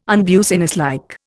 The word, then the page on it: unbusinesslike: Indian